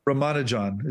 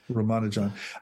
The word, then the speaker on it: Ramanujan: Ramanujan is his name. Ramanujan.